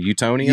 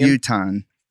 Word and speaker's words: Utahan: What do you say, Utonian? – Utahan. But Utahan. Utahan, okay.